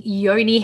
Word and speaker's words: yoni: Now, I understand that you specialize in yoni health.